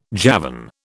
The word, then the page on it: Javan: British English